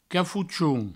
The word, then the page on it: cafochon: cafouchoun